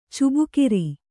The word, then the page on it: ಚಬಕಾರ: cabakāra